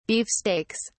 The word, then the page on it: beefsteaks: British English